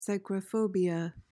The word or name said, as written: psychrophobia